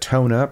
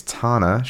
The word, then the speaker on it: Tana: We said Tona, but turns out it's Tana. She says, like Donna with a T.